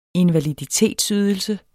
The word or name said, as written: invaliditetsydelse